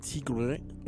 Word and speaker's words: Sigrid: Ella pronuncia su nombre como Sigrid una cosa así, porque ella es de Noruega. Pero nos quedaremos con la pronunciación gringa que es Sigrid. Y el track se llama Strangers. Sigrid, por venir de Noruega, tiene vocales que nosotros no podemos pronunciar y ella misma es así como Sigrid.